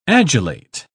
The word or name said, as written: adulate